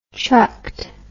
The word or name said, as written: chucked